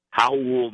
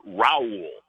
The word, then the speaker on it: Raul: And in fact, we had Howell on our Cavs HQ show, and the Americanized, the English version with the R is Raul, but the correct Hispanic Brazilian pronunciation is Howell, and that's what he goes by.